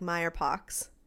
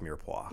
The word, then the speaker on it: mirepoix: It's spelled out like Meyerpox. – It's pronounced mirepoix.